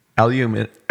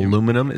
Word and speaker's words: aluminium: Aluminium is correct. – Yes, that aluminium. Aluminum is incorrect.